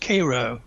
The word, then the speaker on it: Cairo: It's even, at least they call it Cleveland, not Cleveland, because Cairo, Georgia is pronounced Cairo. Cairo, Georgia.